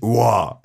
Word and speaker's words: ROOAR: R-O-O-A-R würde ich Roar genau, so aussprechen.